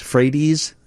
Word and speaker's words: Frates: But that I have his name in front of me, and I'm not sure how you pronounce it. Pete, F-R-A-T-E-S, if it's Frates or Freites, Freites, thank you.